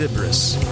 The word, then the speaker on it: Sybris: That's spelled S-Y-B-R-I-S. I believe it's pronounced Sybris.